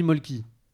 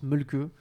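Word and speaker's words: Mölkky: Moi, je dis Molki. Ça se prononce Molke.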